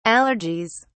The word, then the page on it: allergies: British English